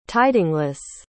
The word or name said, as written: tidingless